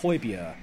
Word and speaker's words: Højbjerg: Think about Højbjerg. You know the player who plays for Southampton. It's pronounced Højbjerg.